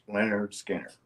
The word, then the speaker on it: Lynyrd Skynyrd: He was looking at it, Lynyrd Skynyrd, pronounced Lynyrd Skynyrd.